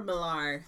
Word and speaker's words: Millar: Does he pronounce it Millar or Millar? That A throws me off. – Millar. – Millar.